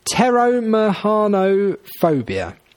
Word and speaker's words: pteromahanophobia: Pteromahanophobia. It's pteró with a silent P at the front, so pteromahanophobia, which sounds like a complicated name.